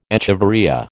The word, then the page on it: echeveria: American English